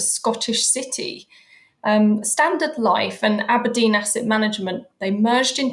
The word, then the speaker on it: ABRDN: It was decided that ABRDN, which is pronounced as Aberdeen and spelt without any E's, was the perfect solution.